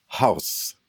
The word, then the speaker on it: Haus: A new release with Fortress Press entitled Keys to Bonhoeffer's House. And I pronounce it that way because that last word is spelled as Germans spell it, H-A-U-S. We might say house here, pretty close.